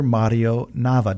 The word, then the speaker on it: Mario Nava: It's Pastor Mario Nava.